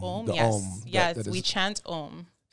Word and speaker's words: Om: So as in O-M. – Om. – Om. Pronounce it right. Om.